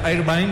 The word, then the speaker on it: Irvine: Irvine es el nombre, así se pronuncia, Irvine, mediocampista, 33 años, juega en Alemania.